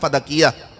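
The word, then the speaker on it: فدک: میں اور آپ جب سیدہ سلام اللہ علیہ کے فضائل پڑھتے ہیں نا, یقین جانیے خطبہ فدقیہ, صحیح پروننسیشن فدق ہے, فدق ہماری اردو زبان میں آ کر تھوڑا زیر زبر کے تحریف کے ساتھ غلط تلفظ کیا جاتا ہے.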